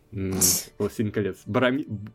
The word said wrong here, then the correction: Боромир: Боромир. – Боромир. Да, да, Боромир, Кто? да. – Я правильно произношу его имя? Ну, Боромир. Вот.